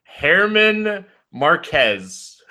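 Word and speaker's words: Germán Márquez: Herman Marquez. That's how she told me to say his name.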